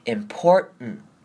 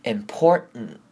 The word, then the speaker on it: important: 好 One of the most important 这边 most 跟 important 也是今天要特别注意的地方首先来看重要这个字 important 我们在念的时候呢会跟之前很多字像 mountain 我们会念成 mountain 一样那我们这边 important 呢我们会念成 important 也就是把后面 T-A-N-T 用这个声音来带过它 important 那要特别注意的是呢，我们不可以把 import 的 r 跟 import 后面的的声音直接连得太相近，这样会变成 important，这样就不对了。